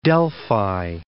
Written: Delphi

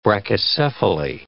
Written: brachycephaly

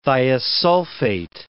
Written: thiosulfate

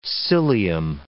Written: cilium

